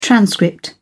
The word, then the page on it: transcript: British English